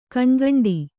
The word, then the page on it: ಗಣಗಂಟೆ: Kannada; gaṇagaṇṭe